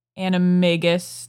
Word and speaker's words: animagus: And I want to talk about Crookshanks for a minute, because I just kept on expecting Crookshanks to be revealed as an animagus.